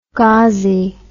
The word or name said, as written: gazi